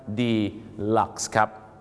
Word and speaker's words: deluxe: อีกหนึ่งคำที่บ้านเราออกเสียงผิดประจำก็คือคำนี้ครับอยากจะได้ห้องพิเศษนะครับไปบอกฝรั่ง Can I have a deluxe เขาจะออกเสียงว่า deluxe นั่นเองครับ deluxe นะฮะไม่ใช่ deluxe นะครับ deluxe ครับพี่น้อง